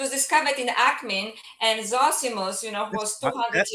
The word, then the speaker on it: Zosimus: Am I pronouncing it properly? Yeah, Zosimus, yeah, yeah, of Panopolis.